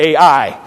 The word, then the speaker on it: Ai: They call it, that's how you say it, Ai.